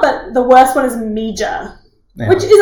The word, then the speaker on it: Meager: Have I pronounced that correctly? – Yes, that is exactly right. It is confusing, but it is Meager.